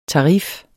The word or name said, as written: TARIFFERNES